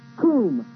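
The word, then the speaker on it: koom (קום): The word arise is the Hebrew word spelled Q-U-W-M. And it's pronounced koom, K-O-O-M would be the pronunciation.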